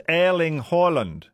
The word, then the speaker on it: Erling Haaland: Erling Haaland is pronounced in Norwegian like, it's Erling Haaland.